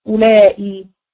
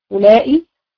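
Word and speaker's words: أولاء: طيب للجمع المذكر أو مؤنث، هناخد لأسماء الإشارة أولائي، أولائي الواو دي لا تنطق، أولائي طيب بالمد عند الحجازيين نقول أولائي، وبالقصر أولى، قصدي وبالقصر أولي، أولائي أو أولي، أولائي أو أولي، أولائي رجال، يبقى أولائي اسم إشارة مبني على الكسر في محل رفع مبتدأ، رجال هتبقى خبر.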